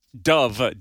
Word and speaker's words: Dove: Dove, D-O-V-E, because two people thought I said Devil Awards for some reason.